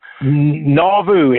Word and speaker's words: Nauvoo: Nauvoo, N-A-U-V-O-O.